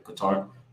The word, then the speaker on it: Qatar: Qatar.